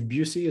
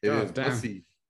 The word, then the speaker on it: Busey: It's Keith Busey. Is that how you say it? – No, wrong. – Busey. Okay. Pardon me.